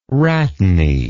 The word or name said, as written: rhatany